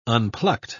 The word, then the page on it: unplucked: British English